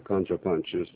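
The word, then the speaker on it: CounterPunch: از جمله مهمترین خبرها که انتشار پیدا کرده یکی رو کانتر پانچ. – بله، درست تلفظ میکنم؟ – بله بله، کانتر پانچ.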